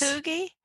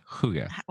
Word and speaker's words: hygge: I think is how it's pronounced. Hygge. – Okay. – The BBC says hygge. That's what the BBC.